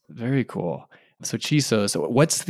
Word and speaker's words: Chisos: Am I saying that right? I am, right? Chisos? – Chisos.